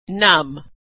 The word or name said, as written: numb